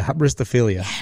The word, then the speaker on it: hybristophilia: Hybristophilia.